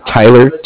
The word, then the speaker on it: Tyler: My name is Tyler, T-Y-L-E-R, okay. – And I'm calling with Cardiff. It's spelled C-A-R-D-I-F-F, as in Frank.